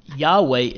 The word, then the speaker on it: Yahweh: Yahweh is a scholarly reconstruction of the original pronunciation drawn from early Greek transliterations, okay?